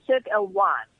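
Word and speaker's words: Cirque Éloize: You nearly got it right. Cirque Eloize. Try to be a bit French, darling, in the pronunciation. Cirque Eloize ID. And the reason it's Cirque Eloize, it's a French-Canadian production.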